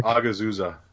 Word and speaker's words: Hagazussa: Is that how you pronounce it? – Hagazussa. Oh, it's close H enough. – Hagazussa. The H is silent. Well, that's a yeah. good. That's a good uh, explanation of how to say it.